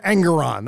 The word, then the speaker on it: Engoron: Yes, we're going with Engoron. That's the official pronunciation of the Bob Seska Show.